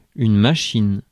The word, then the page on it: guy: /ˈɡaɪ/